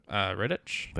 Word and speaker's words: Rittich: Rittich.